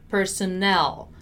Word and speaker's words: personnel: We have personnel and personal, two different stressed syllables, although the words are very similar.